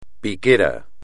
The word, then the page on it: piquera: espagnol